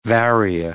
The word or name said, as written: varia